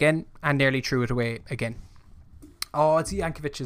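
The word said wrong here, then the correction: Jokanović: is in charge. Yes, Jokanovic. That's how we pronounce it.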